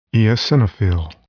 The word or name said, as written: eosinophil